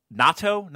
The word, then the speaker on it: natto: Natto? Is that how it's pronounced? – Yeah.